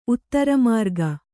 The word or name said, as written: ಉತ್ತರಮಾರ್ಗ